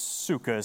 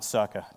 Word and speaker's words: sukkah: And uh, just so you know, it's sukkah is how you pronounce that, not sukkah.